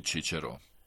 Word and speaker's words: Čičero: Ovdje se ukazuje i na čuvenog starogrčkog filozofa Ciceroa, čije ime Turci izgovaraju Čičero.